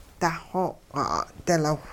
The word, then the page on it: daxooq'i-'a'dilaw: hupa